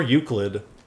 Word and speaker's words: Euclid: Did I say that right? Elucid? – Yeah. – Elucid. – Or Euclid. No, Euclid, like the geometry.